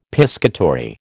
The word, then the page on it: piscatory: American English